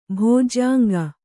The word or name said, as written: ಭೋಜ್ಯಾಂಗ